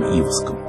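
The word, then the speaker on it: Иваском: Владимир Варшавский в беседе с Юрием Ивоском. Я делаю такое ударение в фамилии Ивоска, потому что в науке об эмиграции так прижилось.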